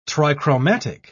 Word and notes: trichromatic: British English

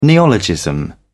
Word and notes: neologism: British English

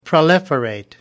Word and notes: proliferate: American English

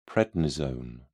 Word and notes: prednisone: British English

